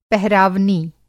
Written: पहरावनी

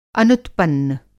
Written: अनुत्पन्न

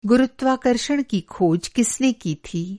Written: गुरुत्वाकर्षण

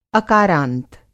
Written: अकारांत

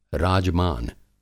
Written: राजमान

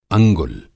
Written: अंगुल

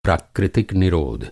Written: निरोध

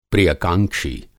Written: प्रियकांक्षी

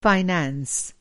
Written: फाइनेंस

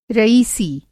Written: रईसी

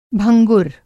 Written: भंगुर

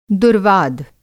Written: दुर्वाद

